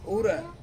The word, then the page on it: ura: Ansus